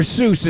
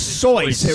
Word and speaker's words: Seuss: Dr. Seuss is not Dr. Seuss. – He's sus, right? – Soyce. No. It's Soyce. It rhymes with voice. When he conceived it in college, that was the way he pronounced it.